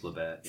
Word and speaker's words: Labatt: I always get corrected. Labatt. – I think it's Labatt, yeah.